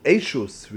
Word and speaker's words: Eshus: Tzodnes or Ishus. Eshus, really, it's pronounced.